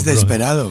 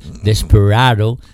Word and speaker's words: Desperado: es desesperado, pero bueno. – Es desesperado, pero Desperado es como lo pronuncia Eagles.